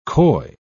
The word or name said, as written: coy